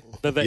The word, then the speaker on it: Vivek: Vivek, yeah, that's what Vivek, he says. – He rhymes with cake.